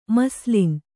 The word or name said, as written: ಮಸ್ಲಿನ್